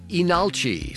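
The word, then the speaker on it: Leenalchi: That's the band Enalchi, spelled like Lee Nalchi, but the initial L is not pronounced.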